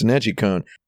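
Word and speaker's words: NeGcon: So it's like, I got a lot of people being like, why are you pronouncing it that way? And it's like, because it's very clear that it's a capital G and it's Nejicon.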